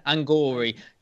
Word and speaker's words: Angourie: How do you say that first name? – Angourie Rice. Yeah. – Angourie.